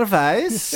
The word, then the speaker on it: Weisz: I don't know why I said Vice. – Weisz. – I just did the German way, but... – She is Rachel Weisz. – Rachel Weisz. I just like pronouncing W's as V's.